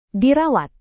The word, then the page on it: dirawat: indonesian